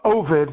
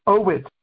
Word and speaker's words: Ovid: In his version of the narrative, of Ovid, that's how it's pronounced in English today, but in Latin, Ovid.